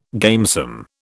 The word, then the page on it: gamesome: British English